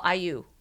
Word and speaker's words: AYU: But then their day club's called IU. – IU. How do you spell that? – A-Y-U. – A-Y-U.